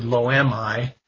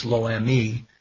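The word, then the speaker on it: Lo-Ammi: Well, if I say the word "lo am I," and by the way, it should be pronounced "lo am me,"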